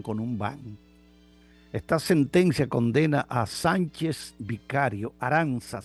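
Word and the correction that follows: Arantxa: Sánchez Vicario, o Arancha, como se pronuncia más bien, se escribe con X, Arancha Sánchez Vicario, 52 años de edad, condenada a 2 años y prisión, 2 años de prisión y multa.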